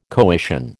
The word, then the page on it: coition: American English